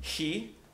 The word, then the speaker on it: chi: So there's a Greek letter, which is the letter he, or some people pronounce it chi, but he would be a little more accurate.